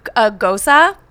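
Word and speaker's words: gose: but it was a gosa. – Oh. – Which I've learned is how you say that.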